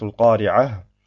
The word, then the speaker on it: al-Qari'ah: In zum Beispiel Surat al-Qari'ah. Wenn ich auf dem ersten Vers stehen bleibe, sage ich al-Qari'ah und habe am Ende ein Ha.